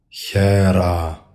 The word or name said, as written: HERA